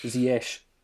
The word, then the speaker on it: Ziyech: Hakim Ziyech, sorry, I should say that's a correct pronunciation. Thank you, Simon Johnson. – Ziyech.